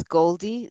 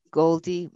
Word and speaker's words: Gholdy: Her name is Goldie. That's spelled G-H-O-L-D-Y, Goldie Muhammad.